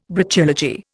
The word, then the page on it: Brachylogy: Indian